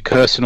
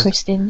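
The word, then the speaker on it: Krysten: Kristen, is it Kirsten or Kristen? I don't know how you say it. – Anyone know? Kristen Ritter? – I think it's Kristen, yeah.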